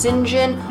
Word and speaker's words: St. John: St. John Rivers, that is spelled St. John, it's pronounced St. John.